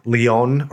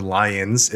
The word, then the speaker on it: Lyon: He lived and was bishop of Lyon, or Lyons, if you want to pronounce it the way Americans do, which is a region in southern France.